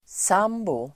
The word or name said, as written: sambo